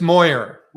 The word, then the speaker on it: Moyer: Am I pronouncing your last name right? Make sure. – It's Moyer.